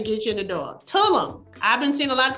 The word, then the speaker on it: Tulum: Tulum.